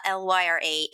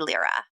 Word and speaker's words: Lyra: I'm on Instagram, CCCECE, Lyra, LYRA, agent. And then I'm on Twitter, Cecilia C. Lyra. – everyone pronounces it Lyra, but it's Lyra.